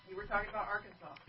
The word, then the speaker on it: Arkansas: Is that Arkansas? Yeah, that's Arkansas.